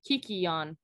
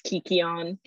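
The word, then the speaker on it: kykeon: but it's those two people, and they're mixing the potion called Kekion. Hold on, I have how to pronounce it in my notes. – Kikion, yeah, thank you. Okay. So yeah, it's Kikion.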